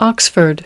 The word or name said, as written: Oxford